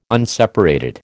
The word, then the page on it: unseparated: American English